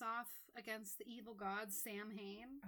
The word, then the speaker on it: Samhain: But yes, it is pronounced Samhain.